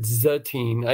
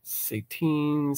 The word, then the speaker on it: Satine: And when they said Satine, I heard Zatine. I even spelt it out, Z-A-T-E-E-N, phonetically, because I thought that. But then as you started talking about that, and I'm like, Satine, Satan, okay.